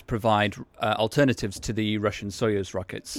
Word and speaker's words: Soyuz: Soyuz.